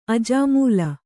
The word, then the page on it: ಅಜಾಮೂಲ: ajāmūla